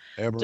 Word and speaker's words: Abramoff: And then we have that Jack Abramoff, is that how you say his name? – Yeah, Abramoff.